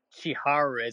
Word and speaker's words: Chiharu: How do you say your name in real life? – Chiharu is my Japanese.